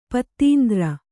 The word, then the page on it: ಪದಾಂತರ: Kannada